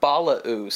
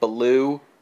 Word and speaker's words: Balaoo: Well, it's Balao. – Okay. – It's French. – Okay, I kept reading it as being like Baloo from Jungle Book. – Yeah, well, it's spelled B-A-L-A-O-O. – Uh-huh. – But, yeah, according to Google Translate, at the very least, it's Balao.